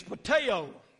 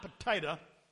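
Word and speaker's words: pateo (πατέω): And that word in the Greek is pateo, not potato, but pateo. That's how it's pronounced.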